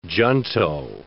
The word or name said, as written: junto